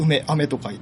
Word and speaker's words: 梅雨: またこの時期はですね、梅の実が実る時期ということになりまして、梅の実が実る雨というところで、こう、梅雨と書いてね、梅雨と読むというような感じですね。